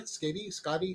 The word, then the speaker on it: Skadi: Skadi? – Yeah, Skadi.